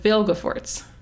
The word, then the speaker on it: Vilgefortz: Sorry, I said Vilgefortz with a lot of emphasis because I realized that I've been saying it wrong. I spell it right in my notes. I spell it right every time. But I've been putting the L after the G, like, in my pronunciation.